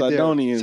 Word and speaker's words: Sidonians: I always mispronounced that, but the goddess of the Sidons. I should have practiced this beforehand. you read Sidonians. – There. Sidonians. There you go.